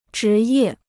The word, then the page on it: 职业: zhí yè